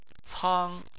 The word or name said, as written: cang1